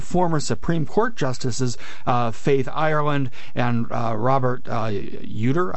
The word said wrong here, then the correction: Utter: I believe it's pronounced, have announced yesterday. – It's Utter.